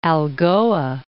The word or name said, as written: Algoa Bay